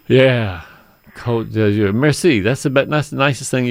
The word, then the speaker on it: Côte d'Azur: It's pronounced La Côte d'Azur.